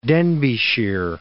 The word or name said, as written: Denbighshire